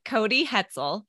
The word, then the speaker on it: Cody Hetzel: Today, we are speaking with Cody Hetzel. Is that how you say your last name? – It is. It's like pretzel with an H.